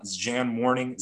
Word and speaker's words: zhanmourning: Uh, there's an account, uh, I believe it's pronounced Jan Morning, Z H A N M O U R N I N G.